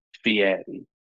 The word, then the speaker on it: Fieri: Fieri. – Thank you. – Say it right.